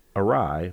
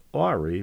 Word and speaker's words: awry: I've made my career in media taking pride in my grasp of the English language, but I confess I thought the word awry was pronounced awry for longer than I care to admit.